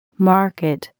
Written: market

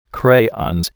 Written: crayons

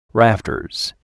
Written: rafters

